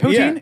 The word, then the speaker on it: poutine: Canadian poutine, or whatever that shit is. Did I say it right? – Yeah, poutine.